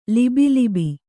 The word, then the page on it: ಲಬೋಲಬೋ: labō labō